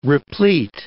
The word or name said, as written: replete